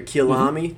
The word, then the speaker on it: Kyalami: So we will be racing in, I believe it's Kyalami, how you pronounce exactly. – That? Yep. Kyalami or Kyalami, mm-hmm.